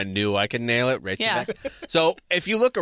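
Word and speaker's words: Reykjavík: Yeah, I mean, especially if you look around and look what Reykjavik like, looks like.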